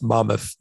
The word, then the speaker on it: Monmouth: It's pronounced Monmouth. It's Monmouth College.